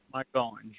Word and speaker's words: Margolin: Margolin.